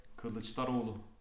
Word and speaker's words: Kılıçdaroğlu: Kılıçdaroğlu.